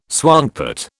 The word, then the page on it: swankpot: British English